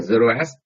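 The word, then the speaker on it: Zoroastre: Và cái vị giáo chủ đầu tiên của nó thì theo truyền thuyết là ông Zarathustra, tiếng Pháp gọi là Zoroastr, đọc tiếng Pháp là Zoroastr, còn Đức Anh nó đọc là Zarathustra.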